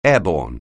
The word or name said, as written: airborne